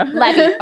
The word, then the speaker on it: Levy: Is that how you say your last name, Levy? – Technically Levy, but like everyone says it differently. So Levy.